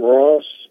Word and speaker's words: Ross: Jakim Ross. Jakim is spelled J-A-C-Q-U-I-M. Last name is Ross, R-O-S-S.